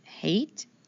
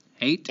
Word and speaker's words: Haidt: How would you say that last name? – I think it's pronounced Haidt. – Haidt. – H-A-I-D like dog, T like Teresa.